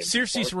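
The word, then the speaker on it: Saoirse: Circe Ronan.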